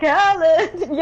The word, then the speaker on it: Khaled: just shout his name and you'll know how to pronounce it. – How? But that's the only way I can say it right. – That's the only way he wants you to say it, okay? – He says it like that. – That's the correct pronunciation. – DJ Khaled.